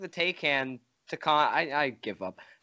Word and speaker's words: Taycan: So, speaking of battery life, how far does the Taycan go? It's Taycan, I think. I'm pretty sure that's how you pronounce it.